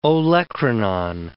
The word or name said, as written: olecranon